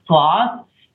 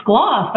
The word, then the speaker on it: Skloss: And now, like, we'll play a show at a club and people are like, that band Skloss. I'm like, wow, they just said it. They just said my name. Without, I didn't have to spell it or, like, tell them it sounds like dental floss.